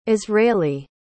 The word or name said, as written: israeli